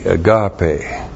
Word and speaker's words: agape: A-G-A-P, long E, agape.